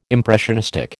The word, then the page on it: impressionistic: American English